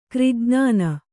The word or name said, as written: ಕ್ರಿಜ್ಞಾನ